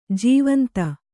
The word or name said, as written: ಜೀವಂತಿ